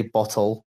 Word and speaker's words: bottle: Yeah, I get when people take the piss, but I say bottle. I pronounce the T's.